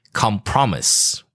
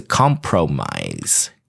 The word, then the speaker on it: compromise: And guys, please don't pronounce it compromise. 不是 compromise, 哦。它是 compromise. Okay, compromise. Okay.